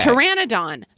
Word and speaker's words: Pteranodon: And Pterodon. – Okay. – Pteranodon. – Pteranodon is like a big pterodactyl. – I left out a syllable in there, but it seems like they're different things.